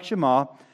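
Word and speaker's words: Shema (שמע): It means hear, but the Hebrew word pronunciation sounds like Shema, but it means to obey and to take action, which is what Shema means also, to conform to.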